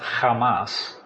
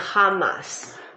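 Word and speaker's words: Hamas (חמס): The word itself is pronounced Hamas. – 这个字呢是念 Hamas。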